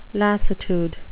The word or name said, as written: lassitude